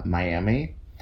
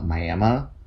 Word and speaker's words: Miami: Because I'm 99% sure that the town in Florida, Miami, and the town in Oklahoma, Miami, are named after the same tribe. – Probably. – But are clearly pronounced very different. People in Oklahoma will be like, Miami? No, it's Miami. And it's spelled okay.